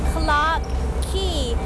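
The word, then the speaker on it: Clarke Quay: ก็จะมีสถานที่นะคะที่มีชื่อว่าคลาร์กคีย์สะกดหน้าตาแปลกๆนิดนึงนะ QUAY แต่คำนี้อ่านว่าคีย์เหมือนคีย์กุญแจละค่ะ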